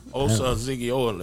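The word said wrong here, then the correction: Osa Odighizuwa: Let me go and get that name out there. See, I know how to pronounce Osa Odigizua. – There you go.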